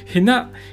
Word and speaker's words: 平成: へな平成と書いてへなと読むん